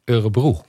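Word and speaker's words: Örebro: Eurebro Universiteit, heb ik het zo goed gezegd? – Eurebroe. – Eurebroe Universiteit.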